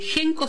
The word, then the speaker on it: Henko: Déjanos tus comentarios, tus mensajes, y si no, lo podés hacer también directo a Instagram, a @espacio.genko.ok, que Genko se escribe H-E-N-K-O,